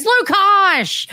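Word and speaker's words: Lukács: Lukash, L-U-K-A-C-S is Lukash.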